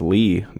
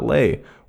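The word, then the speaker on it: Leigh: I also learned that his name is pronounced Lee, not Lay, which is how I pronounced it in the last episode.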